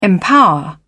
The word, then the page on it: empower: British English